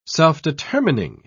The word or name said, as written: self-determining